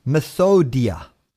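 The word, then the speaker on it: methodeia: is pronounced as methodia.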